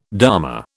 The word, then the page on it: dharma: British English